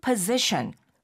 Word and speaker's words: position: I am thinking about applying for that new position. 그렇습니다. Mm-hmm. 그러니까 position, 직책이라는 말, position이라고 하는군요. – Position. – Position. – Z-Z-Z 발음이죠. – 네, 그럼 이걸 position 이러면 못 알아들었겠네요.